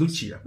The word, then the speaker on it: Lucia: Yeah, it's the Italian pronunciation. Yeah, two discs. – It's Lucia.